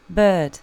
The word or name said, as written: bird